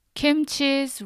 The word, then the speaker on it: kimchi: I see a lot of people pronounce this red fermented cabbage as kimchi. But that's not how we call it. Kimchi's right pronunciation is kimchi. Do you see the difference? Kimchi and kimchi.